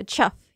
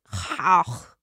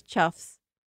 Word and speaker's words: chough: It's also, it's spelled like C-H-O-U-G-H. So to me, I want to pronounce it chauch. Chauch. – Yeah, or like chowg, which chows. Is probably not it. – Chows. Yeah, no. It's not chows, but it's chuffs.